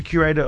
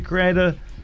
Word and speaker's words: curator: and you're also the creator. – Curator of this week's subject.